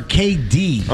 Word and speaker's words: Katie: Is it Katie or KD? – On the sign, it's K-A-T-I-E. – But it's pronounced KD. – It's pronounced KD. Right. I've never... – KD's nuts. – Whoa. That's not disrespectful. – As in K detergent.